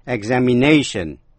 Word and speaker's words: examination: Và danh từ examination, kỳ thi, nhấn mạnh vần ba, tức là vần áp chót.